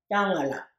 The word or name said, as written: Yangela